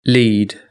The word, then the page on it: lead: British English